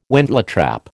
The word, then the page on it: wentletrap: American English